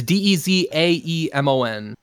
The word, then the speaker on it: Dezaemon: but yeah, it's D E Z A E M O N. Okay. Dezaemon, and then, yeah, it's like Pokemon.